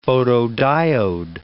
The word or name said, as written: photodiode